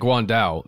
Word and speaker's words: Guan Dao: And especially that Guan Dao, that's the weapon, I believe, is how you say that.